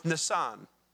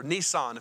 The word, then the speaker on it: Nisan: In the Hebrew calendar, that's the month Nisan, or Nisan, if you want to say it like the car, okay?